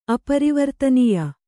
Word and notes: ಅಪರಿವರ್ತನೀಯ: aparivartananīya

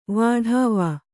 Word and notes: ವಾಢಾವಾ: vāḍhāvā

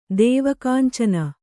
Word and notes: ದೇವಕಾಂಚನ: dēva kāncana

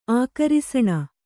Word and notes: ಆಕರುಸಣ: ākarusaṇa

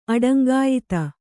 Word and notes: ಅಡಂಗಾಯಿತ: aḍaŋgāyita

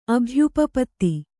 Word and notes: ಅಭ್ಯುಪಪತ್ತಿ: abhyupapatti

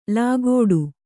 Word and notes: ಲಾಗೋಡು: lāgōḍu

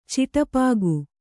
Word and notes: ಚಿಟಪಾಗು: kannada

